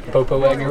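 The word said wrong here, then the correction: Popo Agie: River? – What he meant to say was Poposia.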